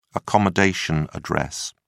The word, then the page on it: accommodation address: British English